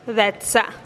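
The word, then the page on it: detsa: Teotitlán del Valle Zapotec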